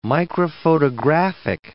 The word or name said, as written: microphotographic